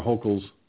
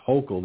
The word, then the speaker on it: Hochul: This is because of Governor Hochul's. And by the way, I've been pronouncing her name wrong. It's Hochul, not Hochul. So I stand corrected.